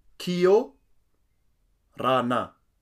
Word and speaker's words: Kia orana: Now, it's important to know the way it's written, it's how it's pronounced. However, if you want to sound more fluent or more like a local, you will say it like this. Kia orana. Let's break this down. Kio rana. Kio rana. Kiorana.